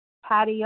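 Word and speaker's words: Patti: Patty O. – How do you spell that? – P-A-T-T-I-O.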